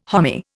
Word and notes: homy: Indian

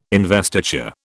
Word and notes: investiture: British English

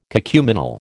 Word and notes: cacuminal: American English

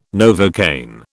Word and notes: Novocaine: British English

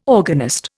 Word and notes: organist: Indian

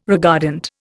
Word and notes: regardant: Indian

